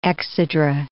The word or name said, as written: exedra